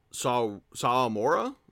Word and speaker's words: Sawamura: Sawamura, am I pronouncing that right? – Yep, exactly.